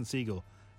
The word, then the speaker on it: Segel: I always thought his name is pronounced differently, but it's Jason Segel, where he's a psychiatrist.